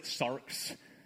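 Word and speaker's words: sarx (σάρξ): The Greek word he used, it just means flesh. Pronounce it sarx.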